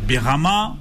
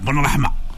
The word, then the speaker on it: Benrahma: Comment je vais le prononcer, Benrahma, ou bien je vais faire l'Algérien, Benrahma.